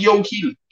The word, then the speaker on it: Joe Hill: Tuvo alguien que decirme en el video de Joe Hill que no se pronunciaba yo, eh, Joe Hill, porque yo le decía Joe Hill.